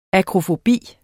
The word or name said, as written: akrofobi